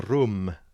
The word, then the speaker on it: Rom: Så jag, nej nej nej Alltså de uttalar ju Rom, alltså rum som Rom Ja varför har jag fått det?